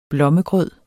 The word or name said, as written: blommegrød